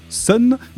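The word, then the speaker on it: Sunn O))): Et n'oubliez surtout pas de citer Sun, qui se prononce Sun, mais qui s'écrit Sun O, parenthèse, parenthèse, parenthèse.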